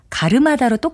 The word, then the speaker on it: 가름하다: 특히 가름하다와 갈음하다는 제가 글자로 쓰는 대로 발음해서 말을 했지만 실제는 가르마다로 똑같이 발음되기 때문에 혼동하는 일이 상당히 많습니다.